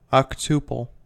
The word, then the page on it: octuple: American English